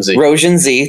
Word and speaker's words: Roujin Z: All right, so released in 1991 and set in 2020, the anime series Roujin Z, is that how you say it? Roujin Z, thank you